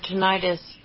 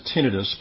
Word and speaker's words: tinnitus: But what I will say about, and I pronounce it tinnitus, but, you know, it's kind of like Caribbean, different ways of pronouncing it.